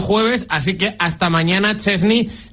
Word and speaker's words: Szczęsny: Szczęsny, que es como se debe pronunciar correctamente.